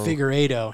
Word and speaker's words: Figueiredo: Brandon Moreno defeats Davison Figueiredo via third round TKO. Doctor stoppage, boys, a looping left hand for Moreno lands inner phalangeal joint first to the eye of Davison Figueiredo. Figueiredo.